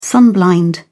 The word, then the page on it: sunblind: British English